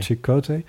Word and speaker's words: Chakotay: Ik zag Janeway en Chakotay staan, of Chakotay, hoe spreek je dat eigenlijk? – Chakotay.